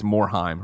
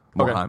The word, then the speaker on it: Morheim: Lord of Pwn corrected my pronunciation. It's Morheim. Rhymes with rhyme. Morheim. Okay.